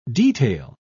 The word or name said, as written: detail